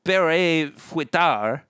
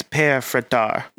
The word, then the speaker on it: Père Fouettard: P-E-R-E is the first name. F-O-U-E-T-T-A-R-D. I know the second name is like Fouettard. – Yeah, it's Père Fouettard. – Yeah, okay, fair enough. So I added one syllable too much.